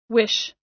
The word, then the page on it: wish: American English